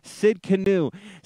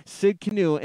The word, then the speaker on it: Tsidkenu: Now, this is a little trickier to pronounce, but this is Jehovah Tsidkenu. Tsidkenu, and that's spelled a little differently. It's Tsidkenu, and that means the Lord of righteousness, the Lord of righteousness.